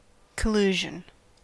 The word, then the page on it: collusion: American English